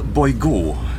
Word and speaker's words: Boygot: Mein Name ist Lumière Boygot, geschrieben mit T am Ende, aber ausgesprochen ohne.